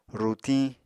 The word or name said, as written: rutiñ